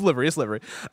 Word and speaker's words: livery: See, that's L-I-V-E, though. That's confusing. L-I-V is 100% live. You don't pronounce that any other way. – Library. – God. – Cool. It's livery.